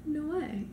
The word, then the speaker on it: Noé: So speaking of wholesome fun, wholesome family-friendly fun, today we're talking about Gaspar Noé. Or Noah? Noah? – Gaspar No. – Gaspar No. – Uh-uh! – How do you say his last name? – No.